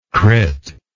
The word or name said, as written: crit